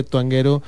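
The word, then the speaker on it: Twanguero: Les recuerdo que Tuanguero se escribe así como lo oyen, pero con W.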